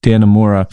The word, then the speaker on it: Dannemora: It's a Showtime miniseries called Escape at, I think, Dannemora is how it's pronounced. Dannemora.